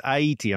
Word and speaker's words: Haiti: And they call the place Haiti. Am I pronouncing that correct? – That's right.